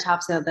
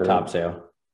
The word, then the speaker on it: Topsail: Topsail. – Topsail? What is it? – So I was told, because I call it Topsail because that's the way it's spelled, but around here it's Topsail.